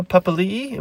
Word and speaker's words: Papali'i: Isaiah Papali'i, am I saying that right? – This, yeah, this story won't go.